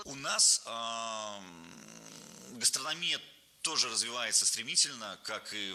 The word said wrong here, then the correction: гастрономия: Не гастрономия, а гастрономия. Ударение в этом слове падает на третий слог.